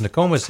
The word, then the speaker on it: Nokomis: They got a break when MCI lost, and honestly, I don't know how to pronounce the team MCI lost to. N-O-K-O-M-I-S. – Oh, Nokomis.